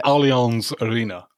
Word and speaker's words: Allianz Arena: In the Allianz Arena.